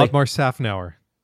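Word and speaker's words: Otmar Szafnauer: Otmar Snafnauer.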